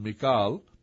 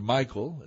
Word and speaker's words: Michal: This is, we already have another question mentioning about this person or involving this person, Michal, or Michael, depending on how you want to pronounce it.